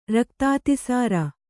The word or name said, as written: ರಕ್ತಾತಿಸಾರ